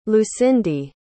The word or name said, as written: lucindy